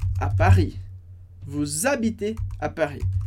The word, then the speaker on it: vous: E aqui, você pode falar, tá, mas a palavra vous, eu não pronuncio o S, porque o S do final da palavra não é pronunciado.